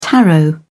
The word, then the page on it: taro: British English